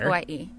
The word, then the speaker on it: Kauai: Kauai.